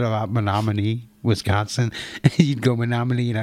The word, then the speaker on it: Menominee: He said, look at it. It says, me no money. And I've never forgot that. Menominee.